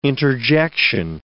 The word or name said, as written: interjection